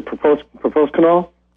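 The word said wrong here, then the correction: Policosanol: Polycosenol. Yeah. Polycosenol.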